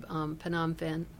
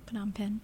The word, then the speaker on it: Phnom Penh: And that'll go out of Phnom Penh. Do I always say that wrong? – Phnom Penh.